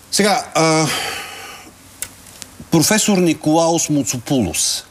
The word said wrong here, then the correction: Моцопулос: Моцопулос, извинявайте. Трудно ми е да... Винаги имам проблем с ударението в гръцките фамилии.